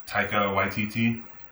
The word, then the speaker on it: Taika Waititi: Uh, the director, uh, Taika Waititi, I think is how you pronounce that. Yeah, nailed it.